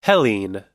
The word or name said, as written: hellene